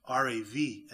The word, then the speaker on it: Rab: The first form was Rab, or R-A-B. It would have probably been pronounced R-A-V, actually, in Hebrew.